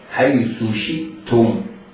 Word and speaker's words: شئتم: فكلوا منها حيث شئتم رغدا، شئتم رغدا، احرص على بيان كسرة الشين حيث شئتم. بعض الناس ينطقها يعني كسرة أو شين مخفية المعالم، لا نعرف حركتها، هي كسرة هي ضمة؟ لا، انتبه لذلك جيدا، حيث شئتم. أوهمني وأنت تنطق الشين المكسورة، أوهمني أنك ستنطق بعدها ياء المادية، أوهمني بذلك، لكن لا تفعل، لا تنطق بالياء المادية.